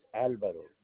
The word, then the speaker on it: Alvaro: My name is Alvaro Cancelado. – Can you spell it for me? – A-L-A-L-V-A-R-O. – A-L-V? – Cancelado.